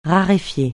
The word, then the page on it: raréfier: francais